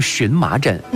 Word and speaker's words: 荨麻疹: 嗯，今天大家也把它读成荨麻疹。